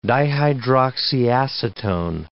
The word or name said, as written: dihydroxyacetone